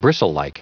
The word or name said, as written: bristlelike